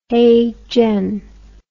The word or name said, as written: heijen